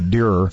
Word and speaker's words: Dreher: to Rod Dreher, uh, Deerer. Yeah, I think it's Deerer is how you say it with American conservative because I want to.